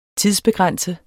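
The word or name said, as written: tidsbegrænsede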